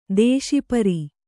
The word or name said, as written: ದುಷ್ಪಾರ